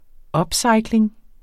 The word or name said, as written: upcycling